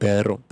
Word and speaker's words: perro: So you see the difference? – There's a difference. – There's a difference. – Pero and perro.